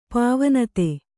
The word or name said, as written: ಪಾವನತೆ